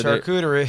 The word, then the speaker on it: charcuterie: And we got a chartreuse board. I don't know if I'm pronouncing that right. – Charcuterie. Did you say chartreuse? – Yep. – No, that's not the word. – Thank you very much. – It's charcuterie. – Charcuterie.